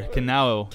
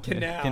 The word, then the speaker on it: Kanao: And then Tanjiro and Inosuke continue to lose the cup game to Kanoe. How do you say your name? Kanoe? – Oh, wow. We had it earlier. Kanao. – Kanao. – Kanao? I don't know. – Kanao, yeah.